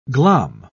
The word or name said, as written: glom